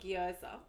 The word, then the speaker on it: gyoza: Oh, gyoza.